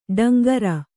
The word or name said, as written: ಟೇಂಕಾರ